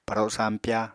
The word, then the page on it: paro sampya: Apatani